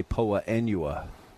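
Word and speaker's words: Poa annua: It's spelled Poa Anua. Some people say Poa Anua. I don't care. – I've heard it both ways.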